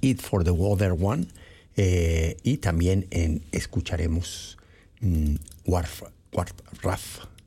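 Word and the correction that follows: Wharf Rat: ¿cómo se pronuncia? Ah, no le atino, déjeme ver. Warf Rat, ellos son Grateful Dead.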